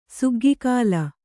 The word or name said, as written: ಸುಗ್ಗಿಕಾಲ